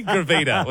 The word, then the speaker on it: gravida: I have actually pronounced that one wrong now because you've ratted me. Gravida was what I was trying to say, which is the medical term for how many times a woman has been pregnant.